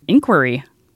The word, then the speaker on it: inquiry: And so I was surprised when I found notes that said, in American English, the most common pronunciation is with the stress on the second syllable, inquiry.